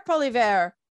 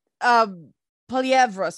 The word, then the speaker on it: Poilievre: Like Pierre Poilievre is Poilievre, sorry, I mispronounced his name.